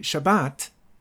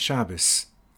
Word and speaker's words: Shabbat: From then on, it would be Shabbat and not Shabbos, Brit and not Bris.